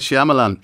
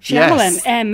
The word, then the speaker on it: Shyamalan: Night Shyamalan. – Shyamalan.